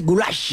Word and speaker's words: goulash: Es que ni siquiera es una carne, el goulash es pronunciado así, goulash.